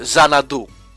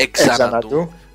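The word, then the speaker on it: Xanadu: Αλλά α πούμε, εξαρτάται και από το τι ακολουθεί. Δηλαδή, α πούμε, ε, Ζαναντού. Δεν προσφέρεται ε, εξαναντού.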